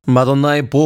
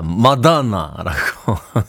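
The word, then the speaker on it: Madonna: 어, M사의 김기덕 DJ가 마다나라고 윗애니 휴튼이라고 발음을 하셔서 어, 당시에 굉장히 유행어 같은 그런 발음이기도 했습니다.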